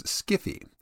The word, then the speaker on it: sci-fi: Like Wi-Fi, sci-fi is a variation on hi-fi and is pronounced as such. In the 1970s, some science fiction fans started to pronounce sci-fi as skiffy.